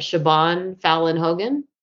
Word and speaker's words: Siobhan Fallon Hogan: So anyway, in this episode 9, The Deal, I think I pronounced her name right, Siobhan Fallon Hogan, Sounds right.